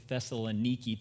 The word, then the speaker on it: Thessaloniki: It's really Thessaloniki today. That's how you say it. Today is Thessaloniki.